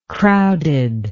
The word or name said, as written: crowdedness